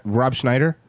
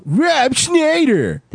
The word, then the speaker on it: Rob Schneider: Rob Schneider? – You didn't say it right. – You didn't say it right. – Oh, Rob Schneider!